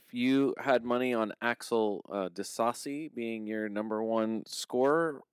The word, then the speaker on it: Disasi: D-I-S-A-S-I. – Disasi. – I'm going Disasi. – Like Disasi. Like sort of saucy, but Disasi.